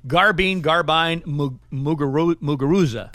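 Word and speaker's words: Garbiñe Muguruza: Garbine Muguruza.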